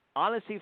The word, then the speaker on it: Annecy: She is currently president of GGB, a material science company that invents and produces engineered products globally, and it is headquartered in Annecy, France. Is that the correct pronunciation, Susan? – It is.